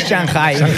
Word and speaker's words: Shanghai: ¿En Shanghai o en Shanghái? – Shanghai para los amigos. – Perdona que tengo mundo, ¿eh? – Se pronuncia Shanghai.